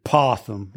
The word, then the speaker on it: opossum: So do you say possum or do you say opossum? – Possum. Yeah, it's possum. The O is silent.